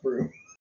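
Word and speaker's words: Bruttenholm: Why is his name pronounced Broom? – It should not be that.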